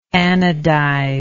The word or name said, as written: anodizer